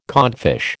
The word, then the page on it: codfish: American English